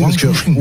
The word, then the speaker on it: Wang Shuxin: T'as vu comme elle prononce ça, ah oui, Wang Shuxin. – Wang Shuxin. Ouais. Alors que ça s'écrit Wang Shuxin. Moi, bêtement, j'ai dit Shuxin, mais non, c'est pas ça. C'est pas Shuxin, c'est Shuxin.